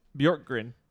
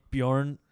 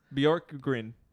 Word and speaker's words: Bjorkgren: Bjorkgren. – Nate Bjorn. – Bjorkgren. – Oh, God. See, here's the problem. We got another guy named Bjorn in Indianapolis. – No, Bjorkgren.